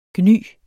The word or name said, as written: gny